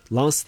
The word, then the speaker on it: Lens: Lens diye mi okunuyor bu? – Evet, ben öyle okuyorum.